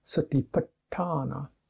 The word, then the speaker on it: satipatthana: But actually the A in sati is short, and the A in patthana is short. The first A is short. Satiput, satiput. But satiput, but the garu syllable lingering on the two T's, retroflex T's, means you get a longer syllable. Satipatthana, satipatthana. So that gives it its particular bounce, you might say.